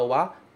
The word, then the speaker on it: wa: A partícula wa também se escreve com o hiragana ha, mas se pronuncia wa.